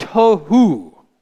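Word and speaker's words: Tohu (תהו): The word form in Hebrew, I'm going to spell it this way in English so that we can pronounce it. T-O-H-O-O. Tohu. Without form. Tohu.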